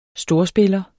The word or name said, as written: storspiller